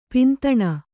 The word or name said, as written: ಪಿಂತಣ